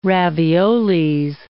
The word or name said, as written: raviolis